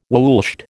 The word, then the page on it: woolshed: American English